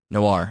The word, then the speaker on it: Noir: Noir.